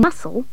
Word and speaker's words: muscle: In muscle, SC is S.